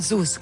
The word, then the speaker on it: Sus: Wie spricht man das aus? Sus, ne? – Sus, genau, Sus richtig.